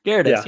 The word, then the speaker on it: Gyarados: But you know Gyarados? – Gyarados, yeah, you said it right.